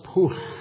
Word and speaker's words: Pwll: There was a little place outside my town, it's spelled P-W-L-L. And it's pronounced poof.